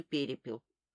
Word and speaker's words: перепел: Есть, знаешь, такая болезнь, называется перепел. Я, как водится, произнесла это слово с ударением на первом слоге.